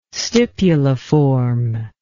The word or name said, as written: stipuliform